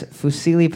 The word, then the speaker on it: fusilli: What shape is fusilli pasta? Am I pronouncing that right, Mr. Kevin? – Yeah. – Sorry. Fusilli. – No, yeah, it's fusilli.